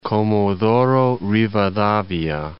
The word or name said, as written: Comodoro Rivadavia